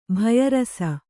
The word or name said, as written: ಭಯರಸ